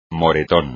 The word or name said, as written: moretón